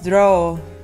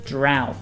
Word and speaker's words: drought: Y normalmente decimos draw. – Draw. – ¿No? Que es como dibujar. – Draw. The correct pronunciation is drought.